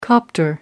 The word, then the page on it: copter: American English